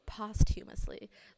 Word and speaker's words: posthumously: Uh, excuse me, released posthumously is that how you pronounce it? – I actually looked it up before I came here. It's actually pronounced posthumously. – Words are hard posthumously.